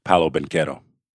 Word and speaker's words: Paolo Banchero: But speaking of doing that, over the weekend, I saw Paolo Banchero from the Orlando Magic.